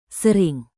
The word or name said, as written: sering